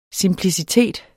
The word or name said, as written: simplicitet